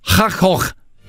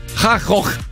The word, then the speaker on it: Gogh: E Martin Deron è olandese e ha detto l'esatta pronuncia di Van Gogh. – Cioè? – Che è Ha-Hoch. – Non è vero. – Ti giuro. Ha-Hoch.